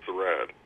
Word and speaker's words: Serad: Hi, it's actually pronounced Serad.